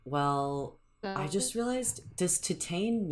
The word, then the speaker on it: Titane: I like Titane. I think that's the correct mm-hmm. way of saying it.